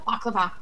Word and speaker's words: baklava: Baklava. Did I say that right? Baklava? – Baklava.